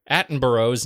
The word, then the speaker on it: Attenborough: Despite your pronunciation of Richard Attenborough, not intentionally incorrect, Attenborough's name, it's pronounced Attenborough. Not since Simon and Garfunkel's Scarborough Fair has the word Borough been so mispronounced.